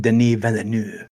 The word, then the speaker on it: Denis Villeneuve: I believe that is pronounced Denis Villeneuve.